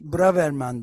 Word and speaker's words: Braverman: Braverman diye okunuyormuş o. – Öyle mi? Braver... Braverman. Yani şey yapmadan önce ee, programdan önce çalışmıştım ve nasıl okuluyor diye bakmıştım. Braverman diye görmüştüm.